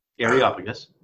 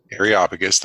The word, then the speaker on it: Areopagus: They took him and brought him to the Areopagus. – Areopagus, thank you.